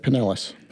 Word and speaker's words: Pinellas: Pinellas.